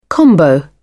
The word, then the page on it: combo: British English